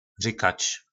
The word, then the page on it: Rykac: po našimu